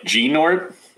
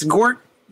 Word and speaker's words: Gnort: You could say Nort. Maybe I think some people think that the G is silent or that the N is silent and it's Gort. – Gort. – But I go Gnort.